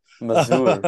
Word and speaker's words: Mazur: All right, so we got Carter Masur. That's how I just want to say Mazur. – it. even though he's from Michigan, so there's really probably no like crazy pronunciation to his name.